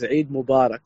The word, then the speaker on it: Eid Mubarak: De juiste uitspraak is Eid Mubarak.